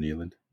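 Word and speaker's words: Niland: How do you say his name? – Neeland. – Is it Neeland? – I would call him Neeland.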